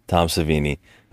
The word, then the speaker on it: Savini: Tom Savini.